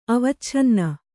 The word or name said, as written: ಅವಚ್ಛನ್ನ